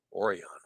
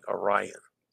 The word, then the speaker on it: Orion: From Vancouver, the Crown Princess and the Viking Orion. I said that wrong. Orion. Where'd that come from? Viking Orion.